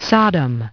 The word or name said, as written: Sodom